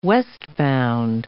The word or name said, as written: westbound